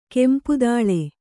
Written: ಕೆಂಪುತೈಲಿ

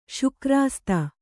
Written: ಶುಕ್ರಾಸ್ತ

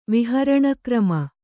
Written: ವಿಹರಣಕ್ರಮ

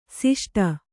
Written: ಷಷ್ಠ

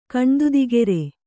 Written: ಕಣ್ದುದಿಗೆರೆ

